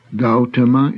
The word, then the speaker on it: Gautama: Gautama is the name of the Buddha. Please note that AU is a diphthong, as in house, and should not be pronounced as in the English word gaudy.